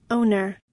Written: owner

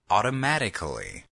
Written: automatically

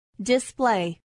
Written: display

